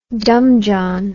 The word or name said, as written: dumb john